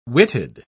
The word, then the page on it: witted: British English